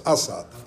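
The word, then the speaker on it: Aszód: The Hungarians have told me that I pronounce it wrong, it's Asad. Yehuda Asad. They do the accent on the first letter in Hungary.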